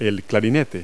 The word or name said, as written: clarinet